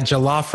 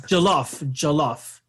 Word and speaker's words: jollof: Yeah, jollof rice. – Jollof.